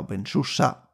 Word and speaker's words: openSUSE: Pues con esto y dándole de nuevo las gracias a El Libre por ayudarnos a organizar el Academy y por supuesto a OpenSUSE, que es como se pronuncia, OpenSUSE,